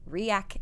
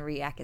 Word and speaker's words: Reiach: If you want to follow me personally for all of my fun life and business adventures, it's at Katie Dunsworth Reiach, and Reiach is spelled R E I A C H.